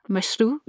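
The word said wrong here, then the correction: Mashrou': Yes, Mashrua.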